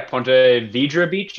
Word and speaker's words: Ponte Vedra: It's at the Players Stadium course in Ponte Vedra Beach. Did I say that right? – Yeah, Ponte Vedra Beach, I think.